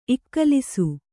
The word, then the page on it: ಇಕ್ಕಲಿಸು: Kannada